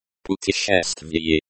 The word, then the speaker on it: путешествие: Путешествие. Путешествие. This is the noun, travel, or journey. This is the key word, and is a noun in the nominative case. The stress is on the third syllable.